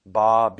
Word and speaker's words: bas: Bas, the S is silent.